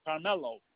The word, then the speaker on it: Carmelo: First name is Carmelo. C-A-R, M as in mother, E-L-O.